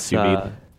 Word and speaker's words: sous vide: I'm not sure how to pronounce it. – Sous vide. It's sous vide. – I thought the D was silent, and I just call it a sous vide. – No, so sous vide is French, and it literally translates to, what is it?